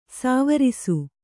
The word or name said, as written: ಸ್ವರಸ